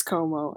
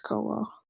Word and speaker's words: Cuomo: I think it's Cuomo. I'm like pretty sure it's Cuomo.